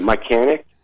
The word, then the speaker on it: Mychanic: It's we pronounce it Mychanic. It is a letter off from Mechanic,